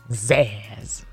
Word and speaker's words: Zsasz: Zsasz.